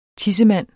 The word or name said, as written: tissemand